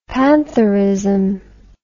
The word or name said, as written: pantherism